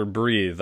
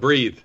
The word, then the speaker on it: Breath: It is Breath, but 80% of the people call it Breathe.